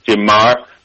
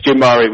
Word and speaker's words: Gin Mare: So, yeah, so it's actually pronounced, it's funny that you say that in the UK and in America, yes, most people obviously read it as a bottle and pronounce it gin Mare, but it's gin Mare we pronounce in Spain. – Well, it's because it's Mare, sea, ocean, sea.